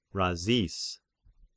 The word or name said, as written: Razis